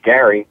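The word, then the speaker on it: Gerry: And an interesting story about Elbridge Gary. His name is mispronounced today. We call it gerrymandering. It's gerrymandering. And that is named after him because Gary was trying to keep radicals out of the first Massachusetts delegation to Congress.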